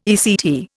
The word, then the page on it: ECT: Indian